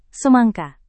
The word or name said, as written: semangka